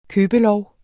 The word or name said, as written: købelov